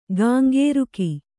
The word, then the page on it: ಕೆಂಕರಿಕೆ: keŋkarike